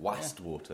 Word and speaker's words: Wastwater: You trying to pronounce Westwater? – No, West. – Okay. – It's spelt with an A. – Oh, right. Wastwater.